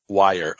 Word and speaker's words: Weyer: Michael Weyer, W-I-R-E? – W-E-Y-E-R. – Okay, I butchered that. Michael, W-E-Y-R-E-Y-E-R. – Weyer. Okay, kind of like Buyer, but Weyer.